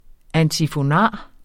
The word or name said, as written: antifonar